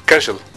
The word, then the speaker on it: Kersh: Kersh. Como Herschel Krustofsky, pero Kersh.